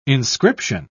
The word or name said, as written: inscription